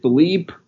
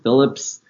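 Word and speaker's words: Phillippe: Also, as for my last name, the question I get a lot is how to pronounce it. Is it Philippe, Philip, Phillips, Philippi? And honestly, it's kind of up to you. It's one of those weird names that's like, oh, I've seen it pronounced this way. And if you're of a Latinx background, it's pronounced a different way.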